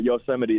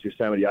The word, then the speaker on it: Yosemite: I didn't know until I was 24 that Yosemite is pronounced Yosemite. I called it Yosemite for the longest time.